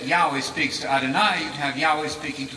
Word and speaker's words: Adonai: But they speak of God as Adonai, Adonai, Adonai. You can remember this, it rhymes with El Shaddai.